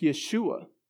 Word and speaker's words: Yeshua: The Hebrew name would have been pronounced Yeshua.